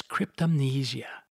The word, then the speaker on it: cryptomnesia: It is known as cryptomnesia. You can Google this word and you will find that Webster's dictionary indicates that it is pronounced just this way.